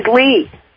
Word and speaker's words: Lee: My name is Lee, and my birthday is 0407. – April 7th, the ace of spades. Yes, April 7th. – What did you say your name was? – My name is Lee. – Can you spell that? – L-E-E.